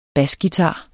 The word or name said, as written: basguitar